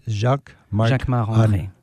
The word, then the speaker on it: Jacquemart-André: Jacquemart-André.